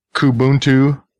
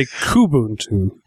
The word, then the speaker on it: Kubuntu: I would say Kubuntu. – How about? – Would say Kubuntu. – Yeah. Kubuntu. That's how I'm going to say it from now on. Kubuntu. So Kubuntu obviously is using the KDE interface versus the GNOME or the GNOME or the GNOME.